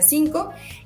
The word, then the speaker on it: CNCO: CNCO, que se pronuncia Cinco y Kenia Oz Plutón.